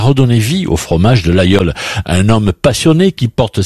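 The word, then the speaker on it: Laguiole: Ça s'écrit l'Aguiole, mais on prononce l'Aïol.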